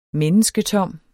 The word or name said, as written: mennesketom